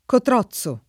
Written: Cotrozzo

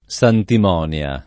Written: santimonia

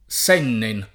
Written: Sennen

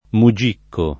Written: mugicco

